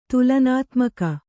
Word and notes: ತುಲನಾತ್ಮಕ: tulanātmaka

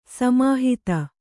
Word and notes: ಸಮೀಹಿತ: samīhita

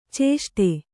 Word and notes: ಚೇಷ್ಟೆ: cēṣṭe

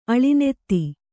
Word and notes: ಅಳಿನೆತ್ತಿ: Kannada

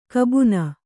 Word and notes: ಕಬ್ಬುನ: kabbuna

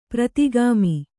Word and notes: ಪ್ರೋದ್ಗಮ: prōdgama

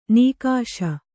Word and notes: ನಿಕಷ: Kannada

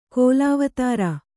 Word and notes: ಕೋಲಾವತಾರ: kōlāvatāra

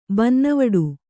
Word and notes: ಬನ್ನವಡು: kannada

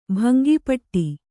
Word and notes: ಭಂಗಪಡು: Kannada

